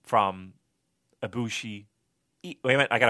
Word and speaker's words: Ibushi: Yeah, stressing the first syllable, Ibushi. Yeah.